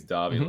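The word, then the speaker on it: Dávila: Is that how you say his name? – I think so. It's got an accent on the A, so I think it's Davila.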